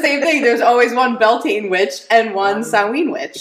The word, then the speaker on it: Beltane: Well, I pronounce it Beltane. There are other pronunciations. Mm-hmm. You have to remember different parts of the world, different ways we're saying things.